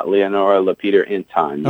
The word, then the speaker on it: LaPeter Inton: Did I say Leonora's name right? – Yeah, Leonora LaPeter Inton.